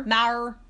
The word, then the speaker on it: Maher: Maher.